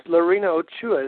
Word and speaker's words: Lorena Ochoa: Yeah, it's Lorena Ochoa, isn't that how you pronounce it? – That's right.